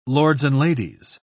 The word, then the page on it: lords-and-ladies: British English